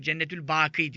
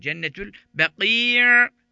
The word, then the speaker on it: Cennetül Baki: Cennetül Baki onu da tabii yanlış telaffuz ediliyor. Cennetül Baki diyor. Baki Allah'ın adı Baki. Baki sonsuz kalan manasına bu. Cennetül Baki sonunda da ayn var. Şöyle bir ayn çatlatmak lazım sonunda.